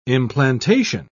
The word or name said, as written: implantation